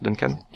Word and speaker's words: Duncan: ça se prononce Duncan Kenya.